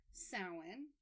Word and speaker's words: Samhain: But yes, it is pronounced Samhain.